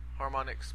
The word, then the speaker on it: Harmonix: Harmonix. – Harmonix, but yeah.